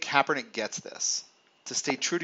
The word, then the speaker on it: Kaepernick: Recently I started saying Kaepernick, but I used to say Copernic. I think just because Copernicus, part of my brain wants to do that.